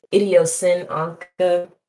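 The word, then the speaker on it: IdiosynAnka: Whenever everybody's like wondering how to say my name, it's Idiosyn Anka. It's like combination idiosyncratic and Bianca. But call me Anka because that's easy.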